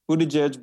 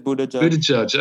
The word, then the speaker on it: Buttigieg: is it Buttigieg? Is that the right pronunciation? I've been struggling with this. Is it? – Yeah. Buttigieg. – Buttigieg.